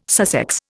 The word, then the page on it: Sussex: Indian